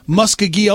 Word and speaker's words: Muskogee: Please say that again. – Muskogee, Oklahoma. – Yep. That's how you say it.